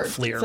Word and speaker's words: FLIR: I think they pronounce it FLIR. But yeah. – Okay. All right. In FLIR? – You have an idea how they pronounce it? – FLIR. – FLIR. I agree. FLIR.